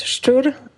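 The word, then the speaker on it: Szczur: Hello, everyone, and the surname is pronounced Stur. And I know it's totally impossible for most of people who are speaking English to pronounce.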